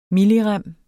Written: millirem

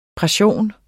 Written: PRESSIONS

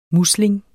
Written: musling

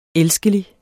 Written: elskelig

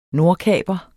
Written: NORDKAPEREN

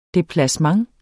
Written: DEPLACEMENT

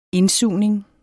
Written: indsugning

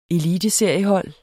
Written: ELITESERIER

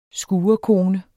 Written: skurekone

